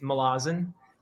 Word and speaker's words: Malazan: Or may I ask, am I pronouncing that correctly? – Malazan? It's fine, however you say it, but I believe the way Erickson would say is Malazan.